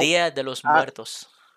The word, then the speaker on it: Dia de los Muertos: Dia de los Muertos. – Thank you. Right. I'm not butchering it on purpose, genuinely, I cannot say it. Dia de la muerte. De los. – De los muertos.